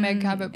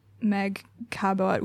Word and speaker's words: Cabot: For those not in the know, Meg Cabot, which her name is actually pronounced Meg Cabot, apparently.